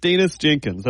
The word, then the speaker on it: Danis Jenkins: But yes, Danis Jenkins, that's the proper pronunciation.